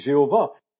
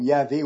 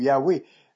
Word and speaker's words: Yahweh: Certaines personnes disent Jéhovah, ce qui n'est pas exact, nous le savons. Certains utilisent parfois diverses prononciations comme Yahvé ou Yahweh.